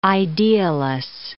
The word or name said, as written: idealess